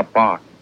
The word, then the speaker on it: Bach: Yeah, Bach.